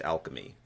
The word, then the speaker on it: alchemy: Just before you get tore up in the comments, Roy, it's pronounced alchemy. – What did I say? – Acclimy, like getting acclimated to, I don't know what you were going for, but it's okay.